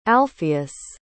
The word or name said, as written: alphaeus